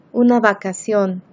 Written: una vacacion

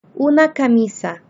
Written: una camisa

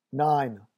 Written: nine

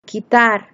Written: quitar

